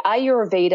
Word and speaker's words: Ayurveda: Right, Ayurveda. And it's actually two words. It's a Sanskrit word, Ayur, which is A Y U R, translates to life, and Veda, V E D A, translates to science.